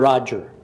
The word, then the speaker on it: Roger: Roger. R-O-G-E-R.